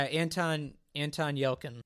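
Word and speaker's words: Yelchin: But my question for you on Green Room is, how was Anton Yelkin? Is that how you say his name? Yeah, Anton Yelkin.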